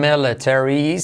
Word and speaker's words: military: Por lo menos la pronunciación military. Si quieres salvar el inglés, habla y como una E, military.